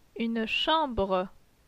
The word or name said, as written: Une chambre